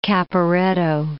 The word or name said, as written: Caporetto